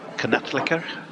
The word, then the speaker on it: knattleikr: but it's Knattleikr.